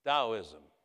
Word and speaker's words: Taoism: Chinese religion, Taoism, T-A-O spelled, but pronounced D-A-O or Dao, D-O-W, Taoism.